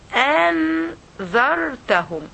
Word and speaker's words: أنذرتهم: you have two hamza, okay, one on the line and one above the alif, so you will pronounce both of them. أنذرتهم أنذرتهم أنذرتهم okay, one by one.